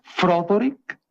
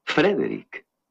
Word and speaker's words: Frederick: ¿Dice usted también Froderick? No, Frederick. ¿Y por qué no es Froderick Frankenstein? Porque no, es Frederick Frankenstein.